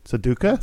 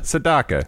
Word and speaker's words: Sedaka: And you're still pronouncing his name wrong. – Sedaka? – Saduka? – Sadaka.